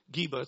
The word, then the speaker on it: Geba: So you see Geba, it's spelled G-E-V-A, but Geba, it's the same place.